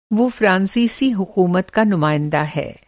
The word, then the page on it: नुमाइंदा: نمائندہ